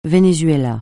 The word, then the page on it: Venezuela: francais